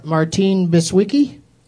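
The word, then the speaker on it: Martine Beswick: And your 5 names are Martine Biswicki, Oba Babatunde. Mm. I know I'm pronouncing Oba Babatunde right because I love that name. I don't know about Martine Biswicki. – That's correct. – That is correct? – Yeah.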